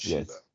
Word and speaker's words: Shiva: So, one of these gods that was fighting was a Hindu god, Shiva. I believe it's Shiva. – Yeah. – Not Shiva. – Yes. – Shiva, I believe is correct pronunciation.